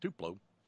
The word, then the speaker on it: Tupelo: Before it was ever Tupelo, T-U-P-E-L-O, pronounced Tupelo, it's just below one plo and above three plo. No, Tupelo.